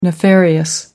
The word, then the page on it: nefarious: American English